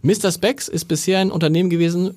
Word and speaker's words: Mr. Spex: Es geht um Mr. Spex.